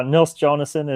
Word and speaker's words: Nils Jonasson: No, not a Nils Jonasson is as close a pronunciation as I'm ever going to get.